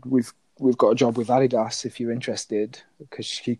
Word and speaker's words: Adidas: No, it's pronounced Adidas. You're right.